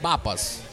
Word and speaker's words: Pappas: Pappas, the P almost sounds like a B. Pappas.